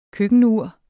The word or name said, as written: køkkenur